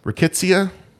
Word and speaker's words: rickettsia: And typhus is a different bacteria that I always mispronounce. It's called rickettsia. Rickettsia? – That sounds right.